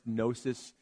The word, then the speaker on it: gnosis: The standard word for knowledge in Greek is pronounced gnosis.